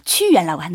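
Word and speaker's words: 취유연이라고 (屈原): 네, 유명한 사람은 우리 춘추 전국 시대 초나라에 있는 신하 구런. – 네, 구런 중국말 발음으로 취유연이라고 하는데요.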